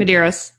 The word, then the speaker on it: Medeiros: Medeiros. Is that right? Did I get that right? – Medeiros.